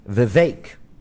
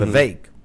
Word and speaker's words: Vivek: People still can't pronounce his name properly. Vivek. You know, like steak? Mm-hmm. Vivek.